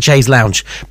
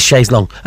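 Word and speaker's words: Chaise Longue: I've got to say it properly because it looks like Chaise Lounge, but it is Chaise Long, of course.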